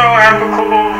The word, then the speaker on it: applicable: You ever study for like 3 or 4 years and some shit and then you're like, wait a minute, this has no applicable value. Applicable is the correct pronunciation.